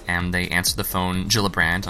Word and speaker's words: Gillibrand: It's actually Kirsten Gillibrand, not Gillibrand.